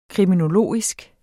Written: kriminologisk

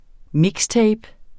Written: mixtape

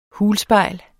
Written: hulspejl

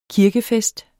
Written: kirkefest

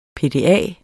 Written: PDA

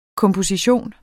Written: komposition

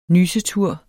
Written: nysetur

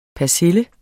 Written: persille

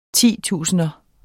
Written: titusinder

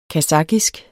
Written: kasakhisk

